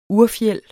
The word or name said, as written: urfjeld